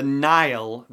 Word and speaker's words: Nihil: The Nihil, that's how it's pronounced, like the River Nile, but spelled N I H I L.